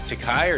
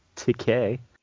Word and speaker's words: Takei: Is it Takai or Takei? – Everyone who knows him knows it's pronounced Takei.